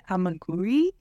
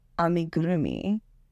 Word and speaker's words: amigurumi: I think I've heard it amiguri, but you are having, there's a whole extra syllable that you're saying in there. – I think I was saying amigurumi, which is...